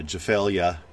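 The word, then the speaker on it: Gevalia: I think it's pronounced with a soft G, so Gefalia cappuccino,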